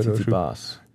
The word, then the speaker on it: Tsitsipas: Stefanos Tsitsipas, habe ich es richtig ausgesprochen? – Tsitsipas.